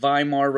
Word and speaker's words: Weimar: This is going to cause another problem because the group that agrees to it is the newly created Weimar Republic. In German, the W is pronounced as a V often,